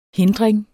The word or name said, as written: HINDRINGEN